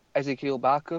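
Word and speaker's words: Ezequiel Barco: Now they're after Ezequiel Barker. Is that how you pronounce his name? – Yeah.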